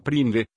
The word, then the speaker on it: PRNDL: El selector de marchas a menudo se denomina PRNDL, que se pronuncia PRINDE, independientemente de las letras o números que se utilicen.